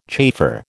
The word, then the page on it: chafer: American English